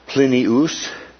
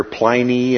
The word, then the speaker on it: Plinius: It's in the Latin, more correctly, it's Plinius. But you'll hear Pliny and different, anglicized pronunciations.